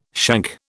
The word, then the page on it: shank: British English